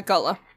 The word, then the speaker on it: Gullah: Yeah, Gullah. That's correct. – Gullah.